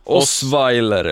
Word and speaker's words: Osweiler: Osweiler.